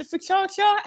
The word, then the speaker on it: focaccia: It is, and I'm going to sort of pronounce this wrong now, but is it the focaccia? How do you say focaccia? – Focaccia, yes, yeah.